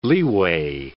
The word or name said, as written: leeway